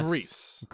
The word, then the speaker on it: Gris: Is it Gris or Greece? G-R-I-S. – Greece, I believe, is what I've heard, yeah.